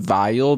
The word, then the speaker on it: Weil: She's also French, but you know what's interesting is that I pronounce her name Weil because she's an Ashkenazi Jew, and there's no W in that language, you know.